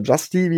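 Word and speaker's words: St-Juste: ist an 74 zum Washington Football Team Benjamin St. Just oder Justy, wie er ausgesprochen wird, den Cornerback, weil ich nochmal sage, das Football Team braucht eigentlich auch Receiver-Verstärkung.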